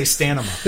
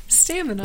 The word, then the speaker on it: stamina: I'm going to say it like I say stanima. – Stanima. – Damn it. – And say it stanima. With confidence. Say, correct me with confidence. Stanima. Stamina.